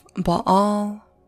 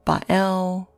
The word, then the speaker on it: Baal: So, as for his name, Baal, there are many variations. You have Baal, you have Bael, Bill, Bell, right? So it depends on the spelling as well. You can spell it B-A-A-L, B-A apostrophe A-L, B-A-E-L, and so on.